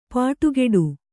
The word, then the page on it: ಪಡಿಕಟ್ಟು: kannada